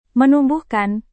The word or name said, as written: menumbuhkan